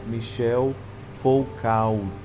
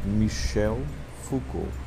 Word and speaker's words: Michel Foucault: Se você for falar como se escreve, fica Michel Foucault. Mas nós somos chiques e falamos o nome dele em francês: Michel Foucault.